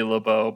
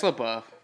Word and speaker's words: LaBeouf: Oh, you would think it would be Lebo, but um, there is an F at the end.